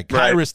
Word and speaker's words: Khyiris: How do you pronounce that kid's name? – Tonga? Kyrus Tonga. – Kyrus. So that is his first name. Okay. Because it's like K-H-Y-I-R-I-S. It's like, is it Kai-I?